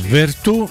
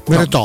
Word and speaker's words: Veretout: Si pronuncia Vertù, Veretù o Veretout? – Veretò. No. – Veretò. Veretò, ok.